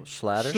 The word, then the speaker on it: Schlatter: And then Shetler, how do we say his name? – Schlatter.